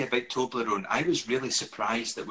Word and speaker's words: Toblerone: Do you say it like that? Toblerone. – Yeah.